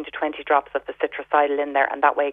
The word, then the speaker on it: Citricidal: They do a liquid one, and that's spelled C-I-T-R-I-C-I-D-A-L, Citricidal.